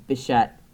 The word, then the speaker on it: Bichette: They had Vlad Guerrero Jr. They have Bo Bichette.